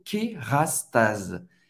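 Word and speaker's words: Kérastase: Kerastase, Kerastase, outra marca que você pode encontrar numa farmácia, uma marca de cosmético, Kerastase. Kerastase. O S, mais uma vez, tem o som do Z, porque ele está entre duas vogais. Clarence.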